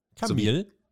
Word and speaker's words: Camille: Camille, sowie, ja. wie Kamillentee.